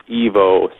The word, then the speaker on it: EVO: I believe it's pronounced Evo, spelled E-V-O.